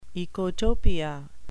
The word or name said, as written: ecotopia